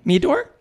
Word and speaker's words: Meador: Meador? – I know. It's pronounced Meador. – Meador. Maggie Meador. – Yes. – Like that's very alliterative. – With a D.